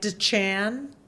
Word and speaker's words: Dichan: I'm on Twitter at Europe Dichan and spelled Dichan just in case. – D-I-C-H-A-N. It's like Dichan, but it's pronounced Dichan.